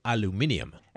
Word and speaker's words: aluminium: Made with, it said on the site, AMS-4045 aircraft and NASA grade aluminium, as the Brits pronounce it.